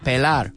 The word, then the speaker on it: pelar: How do you say that? Pelar. Una cebolla.